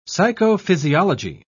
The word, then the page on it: psychophysiology: British English